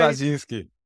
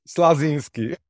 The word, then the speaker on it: Slazinski: Slazinski. Yeah, in English, you can pronounce Slazinski. – I don't mind saying Slazinski.